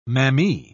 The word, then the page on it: mammee: British English